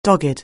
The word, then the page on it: dogged: anglais